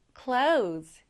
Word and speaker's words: clothes: Clothes. 옷이에요, 옷. 여기서 이 TH는 거의 발음되지 않는다고 생각하시면 돼요. 그래서 clothes. Close. 네, 이렇게 발음해 주시면 됩니다.